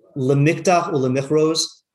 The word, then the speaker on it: Lemikdach Ulemichros: So there is a shir in the Kuntres, otherwise known as, I realized I was corrected that I was pronouncing it wrong. It's Lemikdach Ulemichros is the name of the book of the Kuntres.